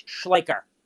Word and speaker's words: Schleicher: It's Schleicher.